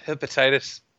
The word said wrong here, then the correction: Hephaestus: Is that how you say it? – It's Hephaestus, right?